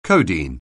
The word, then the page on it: codeine: anglais